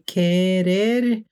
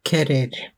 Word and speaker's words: querer: Querer har altså e som den uttalte vokalen i stammen. Så når du sier kerer og tar vekk er, så blir det ke som står igjen. Selv om du har en u der også, så uttales jo ikke den, sånn at det er e som betraktes som stammevokalen i kerer.